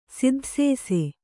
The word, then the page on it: ಸಿದ್ಧಸೇಸೆ: Kannada